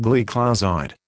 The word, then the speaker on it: gliclazide: Modified release tablets containing gliclazide, pronounced gliclazide.